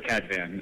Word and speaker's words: Kadvan: I say either Kadvan or Kadvan. What do you say? – The first, Kadvan, is fine.